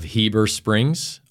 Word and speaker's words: Heber Springs: I'm joined by Andrew Roberson of Dark Threat Fabrication out of Heber Springs, Arkansas.